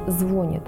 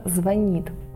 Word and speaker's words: звонить: Нельзя произносить «звонит», только «звонит».